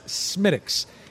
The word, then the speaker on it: Smithwick's: We had the Guinness Stout, and now I'm going to sample the Smittix, and it looks like Smithwick's, but it's not. It is pronounced Smittix.